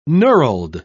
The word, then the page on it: knurled: British English